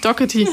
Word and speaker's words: Doherty: Peter Doherty, wie wir es gelernt haben. Doherty. Grüße raus an Manja in Schottland, die uns die Aussprache von Peter Doherty richtig beigebracht hat.